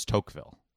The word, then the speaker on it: Tocqueville: But it's T-O-C-Q-U-E. That's what throws me off. It sounds like it would be Tocqueville. No qua.